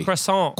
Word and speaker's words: croissant: Croissant. – Croissant. – They don't pronounce the T, croissant.